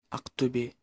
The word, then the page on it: Ақтөбе: ɑqtɵbʲe; Kazakh